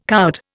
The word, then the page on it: coward: Indian